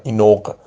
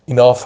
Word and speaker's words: enough: enough رو بعضی بچه اشتباه تلفظ میکردن، استاد فرید خاطره میگفت، اون بچه ها میگفتن اینوق، تلفظش درستش enough هست به معنای کافی.